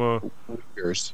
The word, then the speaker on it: Uyghurs: We had a guest from the, how do you pronounce it? The Eager. Uyghurs, the guys from Uyghurs. – The Uyghurs, they're the people that are essentially being enslaved by the Chinese, but of course it's totally off any kind of page.